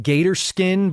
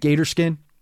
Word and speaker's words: G8RSKIN: You're calling it Gator Skin, but it's spelled G8RSKIN. You're calling it Gator Skin? – Yes.